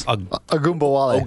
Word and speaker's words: Ogunbowale: Or Dare Ogunbowale. Ogunbowale. Did I say that right? – Pretty close. Ogunbowale.